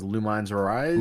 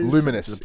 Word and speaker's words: Lumines: I think it was Lumines Arise. – Luminous. It's pronounced the Luminous.